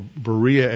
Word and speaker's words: Berea: After leaving Thessalonica, he headed south, went to Berea, or Berea as we pronounce it in English, then to Athens, and then to Corinth.